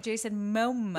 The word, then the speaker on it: Momoa: Jason Momoa.